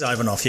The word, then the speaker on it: Ivanov: Is it Ivanov? – It is Ivanov, yes.